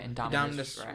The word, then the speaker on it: Indominus: However, for this movie, I felt like because it couldn't go any bigger than the Indominus. – Yeah. – I think I said that right. – Yeah, Indominus Rex.